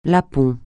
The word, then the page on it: Lapon: francais